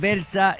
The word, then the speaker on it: Beltza: Belsa 2 Ainhoa así lo dije bien Está perfecto.